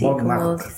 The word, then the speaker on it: Montmartre: Un Montmartre, sí, Montmartre.